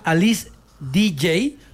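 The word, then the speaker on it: Alice Deejay: Se llama Alice DJ, pero se escribe DEEJAY.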